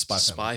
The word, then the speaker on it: Spy x Family: You actually dropped the X, I believe. – I think it's a Spy Family. – I think it's just Spy Family. – Spy Family. – But it's spelt Spy X Family, so take that as you will. – If you want to Google it, chuck the X in.